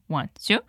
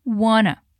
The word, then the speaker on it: wanna: Esse want to pode ser pronunciado simplesmente como want to ou como wanna, que é a junção dessas duas palavrinhas.